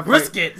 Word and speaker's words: Brissett: Brissett. Say his name right.